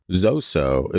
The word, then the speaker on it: Zoso: The user writes, Zoso is the folkloric name pronunciation of Led Zeppelin's untitled fourth album. It's how people, teenage boys in the 1970s, mostly pronounced the sigil of guitarist-songwriter Jimmy Page.